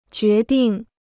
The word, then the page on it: 决定: jué dìng